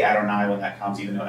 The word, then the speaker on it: Adonai: And in Hebrew, when reading through, it will be pronounced Adonai.